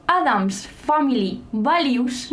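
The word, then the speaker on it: Addams Family Values: Adams Family Values.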